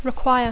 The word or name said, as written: require